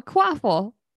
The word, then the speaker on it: Quaffle: A quaffle. – Yes. – Rhymes with waffle.